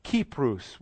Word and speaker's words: Kypros: It was Kypros would be the way they would have pronounced it back then. But Cyprus, because we have different ways of pronouncing those letters.